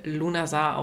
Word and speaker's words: Lughnasadh: Ja, Lunasar wird anders ausgesprochen als geschrieben. Als ich das erste Mal das geschriebene Wort gesehen habe, dachte ich erst so, um Gottes Willen, wie soll ich das aussprechen? Dann kam aber die Erlösung und es wird einfach ganz simpel Lunasar ausgesprochen.